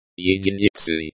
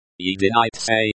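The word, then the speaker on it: единицы: Yedinitsi. Yedinitse, stress on the second syllable.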